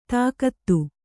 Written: ತಾಕತ್ತು

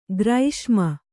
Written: ಗ್ರೀಷ್ಮ

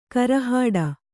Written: ಗುರಿಹೊಡೆ

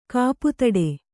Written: ಕಾಪುತಡೆ

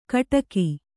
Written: ಕಿಡಿಕೆ

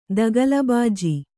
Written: ದಗಲಬಾಜಿ

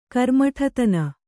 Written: ಕರ್ಮಠತನ

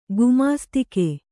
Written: ಗುಮಾಸ್ತಿಕೆ

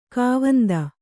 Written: ಖಾವಂದ

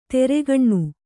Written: ತೆರೆಗಣ್ಣು

